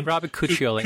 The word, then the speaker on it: Cuccioli: Robert Cuccioli, is that how you say his name? – Robert Cuccioli.